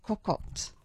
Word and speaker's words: cocotte: Now, we're going to be speaking about breakfast right now, and we're going to be talking about eggs cocotte. Good morning. How are you this morning? – Good, thanks, Julie. How are you? – Now, did I say that correctly? Eggs in cocotte? – Cocotte, yes.